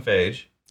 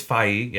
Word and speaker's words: Feige: I called him Kevin Feige time. – We'll touch on that later. – It's Feige, yeah.